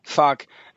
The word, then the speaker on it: Phuk: Earth versus the Wild Hearts, P-H-U-K, which of course we pronounce fuck, Fishing for Luckies, The Wild Hearts Must Be Destroyed and all that.